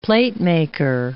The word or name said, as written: platemaker